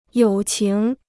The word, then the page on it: 友情: yǒu qíng